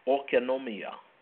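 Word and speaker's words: oikonomia (οἰκονομία): Economy is a Greek word pronounced oikonomia, which refers to the household management, administration, arrangement, distribution, or dispensation.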